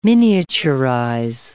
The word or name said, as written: miniaturize